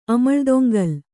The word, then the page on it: ಅಮಳ್ದೊಂಗಲ್: kannada